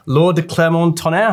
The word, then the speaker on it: Laure de Clermont-Tonnerre: We're nice delighted to meet Laure de Clermont Tonnerre.